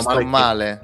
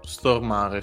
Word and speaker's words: Stormare: La cosa molto interessante però è che eh, Mickey è interpretato da Peter Stormare, penso si pronunci così il cognome. Eh, Peter Stormale. – Che... Stormare. Ah, stornare, ok. Stormare, sì.